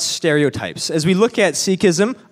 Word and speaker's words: Sikhism: I guess to start, as we look at the topic of Sikhism, now, am I saying that right? Because I've heard different, sometimes we call it Sikhism or is it Sikhism? What's the proper pronouncement? – The official is Sikhism, but we're okay with Sikhism.